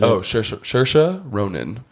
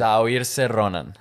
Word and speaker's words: Saoirse Ronan: You said Saoirse Ronan.